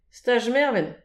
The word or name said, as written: staj mervent